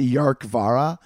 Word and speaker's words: Dearcmhara: He found the did you hear about the Yark Vara? It's not spelt like that. It's spelt like D-E-A-R-C-M-H-A-R-A, but it's pronounced the Scottish, like, Gaelic term of Yark Vara or something like that.